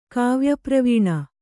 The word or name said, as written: ಕಾವ್ಯಪ್ರವೀಣ